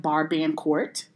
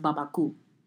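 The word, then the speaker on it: Barbancourt: I mean, when you look at the bottle, it says like Bar Band Court, but it's pronounced Baba Cool.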